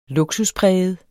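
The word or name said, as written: lukspræget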